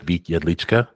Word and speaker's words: Vit Jedlicka: All right, welcome to OYA's podcast, and today we are sitting down with Vit Jedlicka.